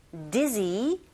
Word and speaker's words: dizzy: This dangerous, dizzy road. 어려운 거 없으시죠, 여러분? D-G라기 아니라 Z 발음은요. 여기 목젖 부분이 살짝 떨리는 느낌을 느끼시면서 발음을 하시면 돼요. D-G 하지 마시고 dizzy, Z, Z, dizzy road.